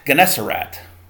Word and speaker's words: Gennesaret: Verse 34, when they had crossed over, they came to the land of Gennesaret. Gennesaret. I actually looked it up and seen how to say it.